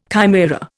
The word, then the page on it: chimaera: Indian